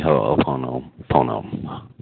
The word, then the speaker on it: Ho'oponopono: All right, let's let's lead in the Ho'oponopono, right? – Okay.